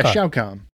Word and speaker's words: Shao Kahn: How do you say it? Shao Kahn. – Yeah, Shao Kahn.